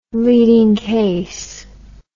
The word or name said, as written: leading case